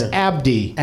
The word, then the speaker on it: Abdi: Barkhad, you got right, but it's Abdi.